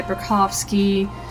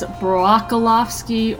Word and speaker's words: Brocofsky: That was Rest of Me by Burkowski. You know, if I didn't have the best vision, I would have read that as Brokolovsky or Broccoli, because it's spelled B-R-O-C-O-F-S-K-Y.